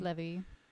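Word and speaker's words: Levee: Levy.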